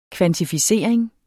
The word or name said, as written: kvantificering